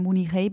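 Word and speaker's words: Mu Ni Hei (慕尼黑): Also dass man eben die Silben nennt im Chinesischen, die dem Wort aus dem Ausland besonders nahe kommen, in dem Fall Mu, also Mü gibt es nicht, das Mü von München, aber es gibt Mu, also nimmt man ein Mu und dann nimmt man Zeichen, die zumindest keine schlimme Bedeutung haben, sondern vielleicht was Neutrales.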